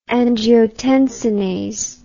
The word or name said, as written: angiotensinase